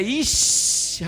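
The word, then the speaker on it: Ixi (איש): E a pronúncia é Ixi, Rapaz, até isso a gente é uma zica.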